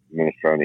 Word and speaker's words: minestrone: How do you say minestrone in Italian? – Minestrone.